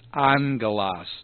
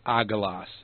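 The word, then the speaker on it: angelos (ἄγγελος): The first one is pronounced like an N, so it's angelos, not agelos. It's angelos.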